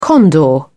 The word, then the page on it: condor: British English